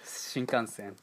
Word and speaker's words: Shinkansen: Yeah, Shinkansen.